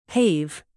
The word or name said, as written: pave